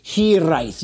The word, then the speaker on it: hiraeth: You can say that with a Welsh, to say it properly, hiraeth. You have to kind of roll the R.